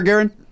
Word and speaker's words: Garen: I said Garen, thinking I said it wrong, because I just said Gary. Mm-hmm. – Garen. – So in my head, it sounded like I was mispronouncing your name, when in fact, I nailed it.